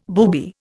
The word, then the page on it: booby: Indian